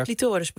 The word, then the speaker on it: clitoris: Want het is pas sinds de jaren 60 van de vorige eeuw dat we überhaupt meer hoorden over de clitoris. – Sterker nog, de clitoris. Die, clitoris, sorry. – Ja, het is altijd die klemtoon.